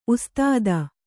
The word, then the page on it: ಉಸ್ತಾದ: kannada